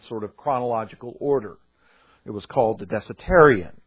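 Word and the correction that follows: Diatessaron: the Dietiserion is what it was called. I said that wrong. The Dietiserion.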